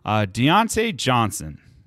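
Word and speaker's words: Diontae Johnson: Next guy, wide receiver for those Pittsburgh Steelers, Deontay Johnson. Did I say that right? – Yeah, you were right.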